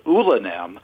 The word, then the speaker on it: Oulanem: He wrote this chilling play called Ulanem, which is spelled O-U-L-A-N-E-M, which is an anagram for Emmanuel.